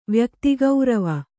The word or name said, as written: ವ್ಯಕ್ತಿಗೌರವ